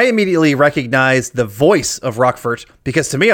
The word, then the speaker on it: Roquefort: Well, it's actually pronounced Roquefort. Roquefort, yes.